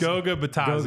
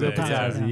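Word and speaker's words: Goga Bitadze: Not to mention, they started, how do you say his name? But Batazzi? Is that Goog? What is it? Goog? – I think it's Goga Batazzi. – Goga Batazzi, yeah.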